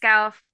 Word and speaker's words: Gough: Gough. How do you say her Goff.